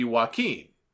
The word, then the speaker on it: Joaquin: It should be Joaquin, and he is the former DJZ from Impact.